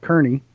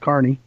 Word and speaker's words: Kearney: There is a restaurant in Kearney, Nebraska, which is spelled Kearney, but pronounced Kearney.